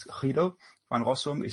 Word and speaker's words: Guido van Rossum: Guido van Rossum, or probably if you pronounce it Dutch, is Guido van Rossum.